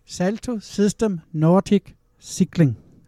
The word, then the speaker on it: Salto System Nordic Cycling: Du er jo formand for, nu skal jeg se, om jeg kan udtale det korrekt, den her nærmest, det er jo en helt spiritus-test. Salto System Nordic Cycling. Er det korrekt udtalt? – Det er rigtigt, ja.